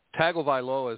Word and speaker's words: Tagovailoa: Yeah, Tagovailoa is how you say the last name.